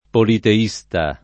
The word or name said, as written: politeista